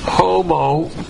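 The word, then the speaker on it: homologeo (ὁμολογέω): The word for confess is homologeo, H-O-M-O-L-E-G, let me spell it on the board here, homologeo, H-O-M-O-L-O-G-E-O, homologeo.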